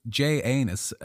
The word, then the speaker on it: Janus: This new date honored Janus, like anus with a J, a two-faced god. That's how it's spelt. I'm just, because my pronunciation might be a little off. – Yeah. – So Jay Anus, a two-faced god who symbolically looked back into the previous year and forwards into the new year.